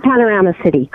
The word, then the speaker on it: Panorama City: Panorama City, correct.